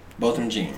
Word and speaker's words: Botham Jean: What's how you pronounce his name? – Botham Jean.